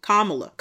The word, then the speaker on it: Kamala: I keep telling you, it's like the same syllable for cop. Kamala. Cop. Kamala.